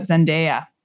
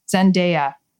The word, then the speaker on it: Zendaya: So let me tell you about Zendaya. And that's how it's pronounced. Zendaya. – Okay, good. Because sometimes I've thought, is it Zendaya or Zendaya? – It's Zendaya.